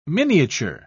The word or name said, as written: miniature